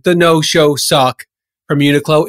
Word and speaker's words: Uniqlo: Some people pronounce it Uniqlo, but I pronounce it Uniqlo.